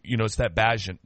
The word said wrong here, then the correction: Bagent: guy. Is that how you say his name? – I think it's just Bagent. B-A-G-E-N-T. Okay. Tyson Bagent.